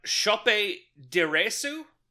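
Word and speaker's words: Sope Dirisu: This movie stars, and I looked up how to pronounce it because I felt like I wasn't getting it. It's pronounced Chope Diresu.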